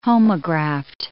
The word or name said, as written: homograft